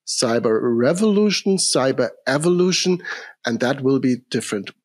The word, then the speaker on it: Cyberevolution: Yeah, I think you already pronounced it accordingly. The word Cyberevolution obviously carries evolution and revolution in it.